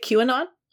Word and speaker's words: QAnon: Is that how you say it, QAnon? I've only ever read it. – Yeah.